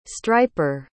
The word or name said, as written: striper